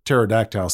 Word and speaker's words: pterodactyl: Just remember that pterodactyl starts with a silent P.